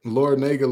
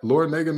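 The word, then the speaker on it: Larrañaga: Is that Laurinaga not Miami's head coach? Like Laurinaga? – Oh, Laurinaga, the nerve of me for pronouncing the A incorrectly.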